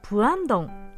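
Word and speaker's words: プ ア ン ド ン (부암동): 先日仕事帰りの友人と待ち合わせをしてチョンログのプアンドンこれは付属の「府に「岩」と書いて「プアン」と読み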